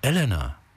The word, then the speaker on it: Eleanor: Eleanor.